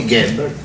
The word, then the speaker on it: Gable: Il y a écrit Gable. On doit prononcer Gable. Ah.